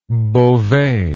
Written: bovet

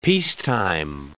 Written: peacetime

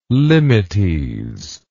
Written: limites

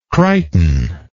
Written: Crichton

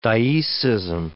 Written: dioecism

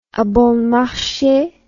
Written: abonmarche

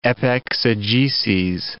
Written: epexegeses

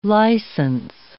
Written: license

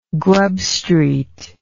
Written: grubstreet

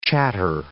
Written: chatter

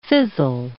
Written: fizzle

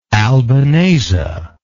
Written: albanese

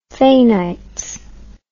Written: faynights

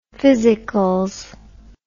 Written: physicals

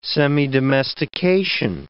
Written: semidomestication

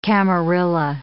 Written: camarilla

